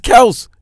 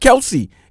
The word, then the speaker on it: Kelce: The father came out and said the name is Kels. It's not Kelce. It's just that people just kept on pronouncing it wrong.